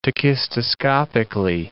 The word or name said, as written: tachistoscopically